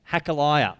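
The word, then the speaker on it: Hachaliah: These are the memoirs of Nehemiah, son of Hakaliah. That's actually exactly how you pronounce it. I looked that up.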